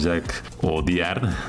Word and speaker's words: Jacques Audiard: No es un documental, no tiene la obligación el director francés Jacques Audiard, así se pronuncia, mi querida Clara, de necesariamente hacer una estampa fidedigna de la vida cotidiana de los mexicanos.